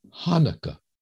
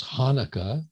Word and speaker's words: Hanukkah: Hanukkah. And I guess Hanukkah is a pronunciation for those who can't quite grasp the ch sound, but I prefer to kind of welcome the ch.